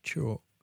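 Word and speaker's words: Xorc: And Chalk, but spelt with an X, X-O-R-C. And I think it's pronounced Chalk, Cas Chalk.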